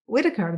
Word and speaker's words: Whittaker: Whittaker, did I say it right, James? – Yes, Whittaker, yeah.